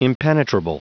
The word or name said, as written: impenetrable